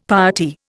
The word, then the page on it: piety: Indian